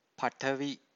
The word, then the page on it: patavi: Pali